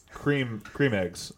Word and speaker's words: Creme Egg: Is that how you pronounce it? Creme egg? – In France. – It's French. – Here it's cream eggs.